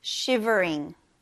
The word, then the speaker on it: shivering: 여기 V 발음 꼭 유념해서 발음해 주세요. Shivering, shivering, shivering.